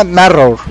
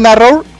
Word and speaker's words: NARR8: Se llama Narrow. Bueno, se lee Narrow, pero se escribe N-A-R-R-8.